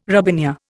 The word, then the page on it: robinia: Indian